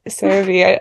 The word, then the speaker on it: CeraVe: CeraVe. – CeraVe.